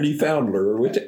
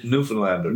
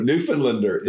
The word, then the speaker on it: Newfoundlander: And I kind of look at him more as a Texan every day than a Newfoundlander or Newfoundler. What's the pronunciation? – Newfoundlander. – Newfoundlander. There we go.